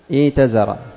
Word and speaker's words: ائتزر: ائتزر، لكن كيف تنطقها؟ تنطقها ائتزر، النطق ائتزر، النطق. إذا ابتدأت بالهمزتين ستبدل الهمزة الثانية من جنس حركة ما قبلها، حركة ما قبلها الكسرة، فستبدل الهمزة الثانية ياء. ائتزر هذا من حيث ماذا؟